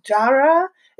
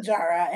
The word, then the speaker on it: Jaarae: The first one is called, um, I think it's pronounced Jara. It's spelled J A A R A E.